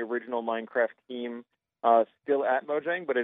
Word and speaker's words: Mojang: The game's developer, Mojang, is that how I pronounce that right? – Mojang, yeah, was the name of the producing, you know, kind of team that was bought by Microsoft a couple of years ago.